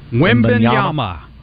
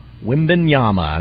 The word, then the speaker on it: Wembanyama: Victor – Wembanyama. – Wembanyama.